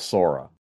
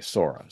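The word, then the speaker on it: Psora: So we say the mother miasm is called SORA. Spelled with a P-S-O-R-A, SORA, SORIC, a SORIC miasm.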